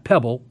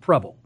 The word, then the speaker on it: Prebble: It's by Lucy, forgive me if I say this incorrectly, Preble, not Pebble, Preble. – Okay. – P-R-E-B-B-L-E.